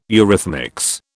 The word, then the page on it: eurythmics: British English